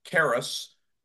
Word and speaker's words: Caerus: It's spelled C-A-E-R-U-S, Caris.